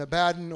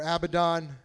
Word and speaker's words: Abaddon: Okay, Apollyon and Abaddon or Abaddon, it can be pronounced both ways.